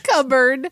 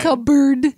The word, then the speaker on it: cupboard: Well, but the P is standing in for a B. The B really wants to be a B. – Cupboard. – Yeah. – Cupboard. – When you were a kid, you probably thought it was spelled like C-U-B-B-A-R.